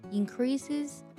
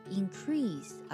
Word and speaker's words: increase: 除了 worry about 这个短语之外，我们还要学习一个单词 increase，指的是增加的意思， increases their chances，增加了他们的几率。在读这个单词的时候我们要注意读作 increase 而不是 increase，一定要区分出这两个音的不同。